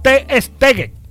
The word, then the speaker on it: Ter Stegen: Así que Stegen, ¿cómo se pronuncia este alemán? ¿O Stugan? ¿Ah? Ajá, ya vamos a ubicar eso. Eso es en alemán. Der Stegen. Bueno, en alemán, Ter Stegen. Se pronuncia eso.